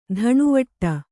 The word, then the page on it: ದಣಿವಟ್ಟೆ: daṇivaṭṭe